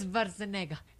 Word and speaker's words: Schwarzenegger: Pero amiguito, mire, es Schwarzenegger. – Es Schwarzenegger. Para nosotros nos enseñaron. Es Schwarzenegger. – Ah, sí. Pero entonces todos los doblajes de las películas se han sido mal. – Mal, todos mal pronunciados.